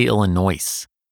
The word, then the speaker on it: Illinois: There's an S at the end of that. Why don't we say Illinois? Because the French hate to pronounce the last letter, or sometimes the last few letters, of any word.